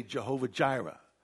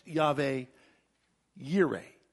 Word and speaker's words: Yahweh Yireh: Sometimes we say Jehovah Jireh, but it's pronounced Yahweh Yireh, the Lord my provider.